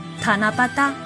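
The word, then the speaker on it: たなばた: 古时日本有神事活动塔那巴达，写成汉字就是棚屋的棚和机器的机，日语发音是塔那巴达。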